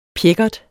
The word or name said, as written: pjækkert